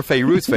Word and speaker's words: Fairuz: I remember you and Eric made a special trip over the weekend to Boston so you could go to a Fairuz concert. – Oh, and you actually said her name correctly. You called her Fairuz.